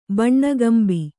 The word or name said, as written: ಬಣ್ಣಗಂಬಿ